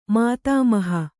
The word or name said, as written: ಮಾತಾಮಹಿ